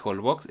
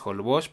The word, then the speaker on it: Holbox: Dato curioso, en Yucatán y en Quintana Roo la X se pronuncia como sh, entonces no es Holbox, es Holbox por decirte algo.